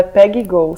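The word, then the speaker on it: Peggy Gou: O nome dela é Peggy Go, se escreve P-E-G-G-Y espaço G-O-U, Peggy Go.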